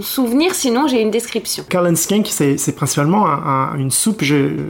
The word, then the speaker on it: Cullen Skink: Dis-moi si je prononce bien, c'est une soupe qui s'appelle le Cullen Skink. – Cullen Skink. – Voilà, Cullen Skink.